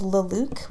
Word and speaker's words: Luluc: So I went to a concert a couple months ago by a band called Luluk, which is spelled L-U-L-U-C.